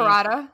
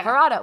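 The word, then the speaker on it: Paratha: They had this one thing called like a patara or something like that. I'm butchering the name. – Parada. – Yeah, yeah. Parada, which is essentially a quesadilla.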